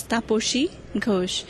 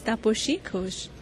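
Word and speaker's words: Tapasi Ghosh: I sort of halted a little bit because I'm from India, and my name is actually in India is pronounced as Taposhi Ghosh, but since it's spelled as T-A-P-A-S-I, everyone here calls me Taposhi. I can't blame them because that's how it's spelled in English, but the actual pronunciation is Taposhi Ghosh.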